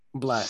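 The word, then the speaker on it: 6LACK: How do you pronounce his name? – Black. Six L E K. If I oh, black. Honest, That's black.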